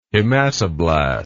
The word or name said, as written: hematoblast